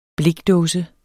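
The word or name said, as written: BLIKDÅSER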